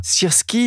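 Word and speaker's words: Syrskyi: Il y a eu aussi un changement, un changement d'ordre militaire avec le remplacement du général Zaloujny par Oleksandr Sirski, je ne sais pas si je prononce bien son nom, vous le faites bien mieux que moi. Qui est ce nouveau général et que peut-il apporter alors, euh, le général euh, Sierski, et, ta prononciation, Pierrick, est, très bonne.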